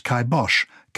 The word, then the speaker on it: kibosh: In Gaelic language, it was a caipin bash, shortened as kibash, kai meaning cap, and bash meaning death, but pronounced kibosh, kai rhyming with I, and bosh rhyming with the German name Bosch.